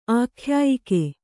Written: ಆಖ್ಯಾಯಕ

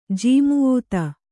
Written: ಜಾಮಾತ

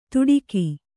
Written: ತುಡಿಕಿ

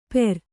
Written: ಪೇರ್

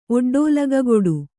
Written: ಒಡ್ಡೋಲಗಗೊಡು

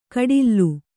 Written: ಕಡಿಲ್ಲು

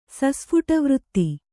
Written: ಸಸ್ಫುಟವೃತ್ತಿ

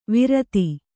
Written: ವಿರತಿ

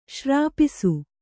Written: ಶ್ರಾಪಿಸು

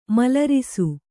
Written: ಮಲರಿಸು

